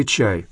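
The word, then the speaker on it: ча (茶): Но нет сомнения, что именно буддийские монахи популяризировали чай сначала в Китае, а потом в Японии. Оба народа обозначают этот напиток одним иероглифом, хотя произносят его по-разному. Китайцы – ча, а японцы – тя.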